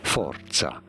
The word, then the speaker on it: forza: For reference, in Italian, this is said as forza. – Forza. – See, there's like a T in there. – You gotta roll your R's, apparently.